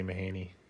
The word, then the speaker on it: Mahaney: It flows when you say Mahaney. – Yeah, but his fan bam goes Mahaney. – Yep. – As if there's two N's and no E.